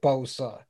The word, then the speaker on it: Bosa: Bosa.